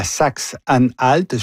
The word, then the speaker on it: Saxe-Anhalt: Donc, élection importante demain dans la Saxe-Anhalt.